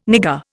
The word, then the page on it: nigger: Indian